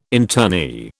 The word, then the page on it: internee: British English